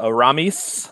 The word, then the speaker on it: Aramis: My uh, next one, I'm going to pick uh, Aramis.